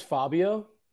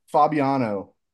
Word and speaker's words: Fabiano: Did you say his name was Fabio? – Fabiano.